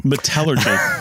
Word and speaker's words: metallurgy: Did I just mispronunciate that? – Metallurgy. Yes. You put the emphasis on the wrong syllable.